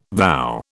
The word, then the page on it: vow: British English